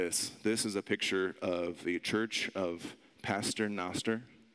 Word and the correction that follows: Pater Noster: Uh, Pater Noster, that's what it is. Butchered that. and what it does is this place is cool and it's trippy.